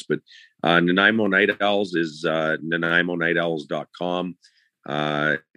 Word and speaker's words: Nanaimo: Nanaimo is how you say it, and the Night Owls are the team, and you know, we're excited about bars because you have an alternate logo.